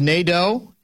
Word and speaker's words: Nadeau: But according to this story, the motion passed on a voice vote after Danny, is it pronounced Nadeau? N-A-D-E-A-U.